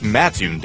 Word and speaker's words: Mattoon: It's Mattoon, dummy.